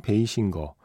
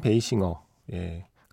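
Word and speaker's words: Basinger: 2861번 쓰시는 분 신청곡 나인하프이크 OST 미키루크 킴 베이싱거 라고 쓰셨는데 원래 발음은 킴 베이싱어에 가까웠죠.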